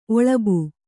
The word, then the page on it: ಒಲ್ಲಭೆ: ollabhe; Kannada